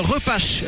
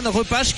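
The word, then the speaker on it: Repas: Non, mais ça s'écrit comme ça. Ouais. Ça s'écrit comme ça, R-E-P-A-S. J'ai demandé à un confrère comment on prononçait. A priori, c'est comme ça.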